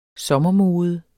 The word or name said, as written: sommermode